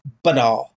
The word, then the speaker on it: BNR: It is what I refer to as my BNR, pronounced banar.